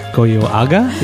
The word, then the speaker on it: Goyoaga: Goyoaga? Is that how you say it? – Yes, perfect.